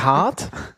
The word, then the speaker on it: Art: sowas zu machen in der Hard, weil in der Art, nicht in der Hard. Das Problem bei Französischsprachiges, wir können die H nicht aussprechen, dementsprechend, weil wir frustriert sind, wir packen die überall.